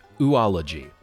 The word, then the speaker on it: oology: The word is oology. O-O-L-O-G-Y, so it kind of looks like oology, but it's pronounced oology.